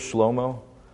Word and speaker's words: Shlomo: Which, side note, did you know that like his name pronounced in the Hebrew is Shlomo? Literally, that's his name in Hebrew, Shlomo, which is a little less regal in my opinion, right?